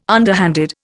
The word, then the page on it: underhanded: Indian